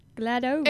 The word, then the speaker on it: GLaDOS: But it's spelled with an O. Glados.